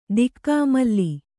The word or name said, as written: ದಿಕ್ಕಾಮಲ್ಲಿ